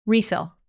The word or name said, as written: refill